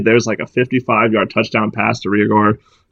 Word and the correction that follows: Reagor: I hope I'm saying his name right. And I think I'm going to take the over here, even though my gut tells me not to. – Well, I believe it is Jalen Reagor.